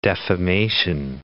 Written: defamation

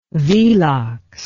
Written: velox

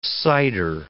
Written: cider

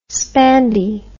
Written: spandy

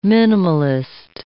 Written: minimalist

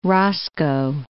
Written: roscoe

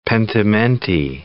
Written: pentimenti

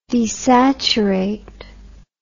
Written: desaturate

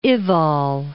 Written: Ewald

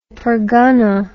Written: pergana

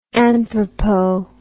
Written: anthropo

